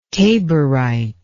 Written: taborite

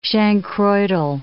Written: chancroidal

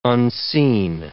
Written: unseen

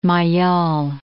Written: Maillol

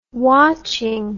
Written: watching

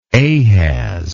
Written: Ahaz

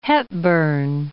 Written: Hepburn

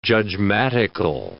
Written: judgmatical

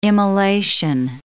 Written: immolation

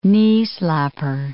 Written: knee-slapper

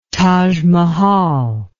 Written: tajmahal

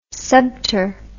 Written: subter

